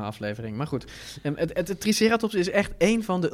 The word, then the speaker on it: Triceratops: Triceratops, of zoals jij altijd zegt, Triceratops. – Ja, ik zeg Triceratops. – Laten we dit consistent... Dat zei je vroeger, ja, dat is gewoon de klemtoon in het Nederlands.